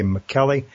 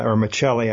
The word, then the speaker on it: Michelli: courtesy of the Ritz-Carlton Hotel Company by Joseph A. McKelly. Or Michelli, I should say, probably is the correct pronunciation, and also the author of best-selling The Starbuck Experience.